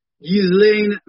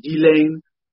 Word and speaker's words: Ghislaine: Jeffrey Epstein confidant Ghislaine, and the pronunciation, I think the proper French is Ghislaine, was arrested Thursday morning in New Hampshire.